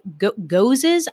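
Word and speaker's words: gose: And by the way, gozes, I definitely have been mispronouncing that. I don't know how many people I said like ghosts or whatever.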